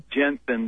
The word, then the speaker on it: Jensen: Jensen, that E is silent.